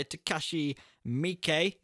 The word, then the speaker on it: Takashi Miike: This film was directed by Takashi Miike, is how you pronounce that.